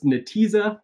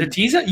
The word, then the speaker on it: Netezza: So like Vertica, or I think it's pronounced Netezza, but I've heard Netezza.